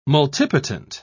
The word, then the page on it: multipotent: British English